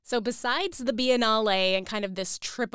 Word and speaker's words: Biennale: And a lot of times people are intimidated to tackle something like the Biennale. Is that how you say it? – Yes, the Biennale.